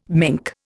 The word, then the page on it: mink: Indian